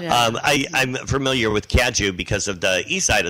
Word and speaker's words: Cajou: Am I pronouncing that right? – You are. – Cajou? – Yeah. Cajou is good.